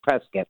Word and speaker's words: Prescott: If you're from Arizona, it's Prescott. – Yes, Prescott. It's like biscuit, but you know, because I always feel like, am I pronouncing it right?